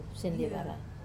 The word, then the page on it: sendevara: Wamesa